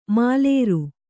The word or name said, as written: ಮಾಲೇರು